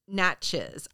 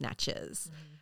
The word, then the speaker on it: Natchez: Number three, Steamboat Natchez. I had to look this up. It's spelled Natchez. I want to say Natche, but I think in Cajun it's pronounced Natchez.